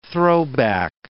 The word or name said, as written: throwback